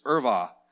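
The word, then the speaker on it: ervah: It's irva, is how it's pronounced.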